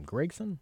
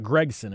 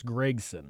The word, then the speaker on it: Gragson: How do you say his last name? Gragson? – Gragson, I believe. – Gragson? Yeah. It's not Gregson. It's Gragson.